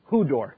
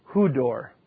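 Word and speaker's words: Hudor (ὕδωρ): It's always the long O sound. Always. And then this final thing is the R sound. Hudor. Hudor. Get my accent right. Hudor.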